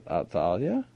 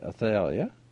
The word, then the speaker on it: Athaliah: And she, and some people pronounce it Athaliah, some people say Athaliah, and either way anybody likes to pronounce it is good for me.